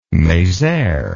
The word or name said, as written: mezair